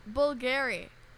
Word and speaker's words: Bulgari: Bulgari. – Bulgari? But has it. – Bulgari? – Oh my God, that V is a U? – That V is a U, yeah.